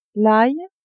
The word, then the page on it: ail: female